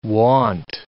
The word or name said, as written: wont